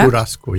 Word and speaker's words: Churrasco: Ich habe schon, Churrasco habe ich jetzt richtig ausgesprochen, oder? – Churrasco, ja.